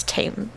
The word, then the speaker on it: Thames: T-A-M-E-S? – Not T-H-A-M-E. It's not a th. – No, then it's... – It's Thames.